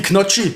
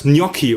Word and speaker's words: Gnocchi: Genauso wie Knocchi, ne? Ich fress heute Knocchi. – Oh, ey, rast ich so aus. Wie spricht man es richtig aus? Gnocchi, oder? – Gnocchi, ja.